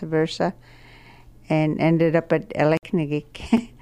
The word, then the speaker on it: Aleknagik: I'm from Aleknagik. The way we pronounce it is Aleknagik, and the root word for this term means a wrong way.